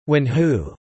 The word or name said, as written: Wenhu